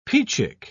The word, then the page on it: peachick: British English